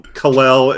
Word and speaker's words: Kal-El: You're gonna tell me that you're gonna pronounce it Hack Man, but you're gonna call him Kal-El and Jor-El.